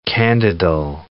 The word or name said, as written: candidal